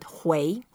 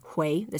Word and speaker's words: 回: Okay, the way to pronounce it, 回. – 回. – 回, the second tone. – And for English people who are reading the pinyin, it's H-U-I, and some people would say it's hui.